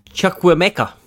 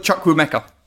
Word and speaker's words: Chukwuemeka: How do you say his name? – Caleb Chukwuemeka. – Yeah, that's actually right. – For fuck's sake, you've ruined the end of the podcast. – There we go. – It's just basically how it's spelt, but you don't really pronounce the E.